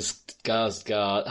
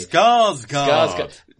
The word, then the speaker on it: Skarsgård: Yeah, it's fascinating to see Stellan Skarsgård. Oh, fuck's sake, I can't say his name today. – Skarsgård.